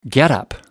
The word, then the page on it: getup: British English